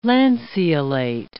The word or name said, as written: lanceolate